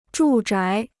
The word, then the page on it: 住宅: zhù zhái